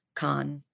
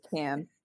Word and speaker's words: Cannes: Con, how do you guys say it? – It's Can. – I butcher it. I say Can too, yeah.